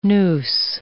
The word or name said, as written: noose